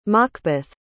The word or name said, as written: makbeth